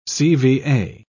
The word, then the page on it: cva: British English